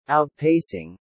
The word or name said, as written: outpacing